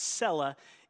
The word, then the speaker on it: Selah: So I want you to know why I pronounce it Selah, because it's pronounced Selah in the Hebrew. But I'm okay if you say Selah, because we all butcher Hebrew names and words.